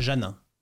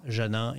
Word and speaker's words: Janin: Janin. – Janin. – Janin.